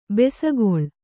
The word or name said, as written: ಬೆಸಗೊಳ್ಳು